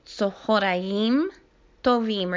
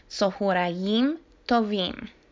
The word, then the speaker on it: Tzohorayim Tovim: Tzohorayim Tovim. Remember to put the accent on the last syllable of the word. Tzohorayim Tovim.